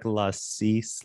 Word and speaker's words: Lassise: You did, even without like a pre before this, like prompting of, okay, it's like Lassise, like La Stop, like.